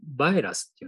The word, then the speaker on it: virus: これ、英語風に発音する場合はまた違うんだよね。あの英語の場合なんだ、バイラスっていうのかな。